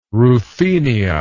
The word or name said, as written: Ruthenia